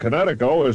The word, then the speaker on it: Kinetico: Everybody knows that Kinetico is spelled K-I-N-E-T-I-C-O. – I thought the instructor said Connecticut. Oh, son, should have remembered kinetic as in kinetic energy with an O on the end. Kinetico. – Kinetico. Spell it K-I-N-E-T-I-C-O.